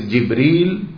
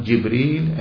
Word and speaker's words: Jibreel: He's pronounced two ways, Jibreel. The Quran used this term, Jibreel and Jibreel.